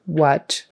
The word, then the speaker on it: what: So we've got what, spelled with an A, but you say it like a short U, what.